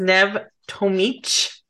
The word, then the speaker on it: Nev Tomic: Um, so today we have with us Nev Tomic.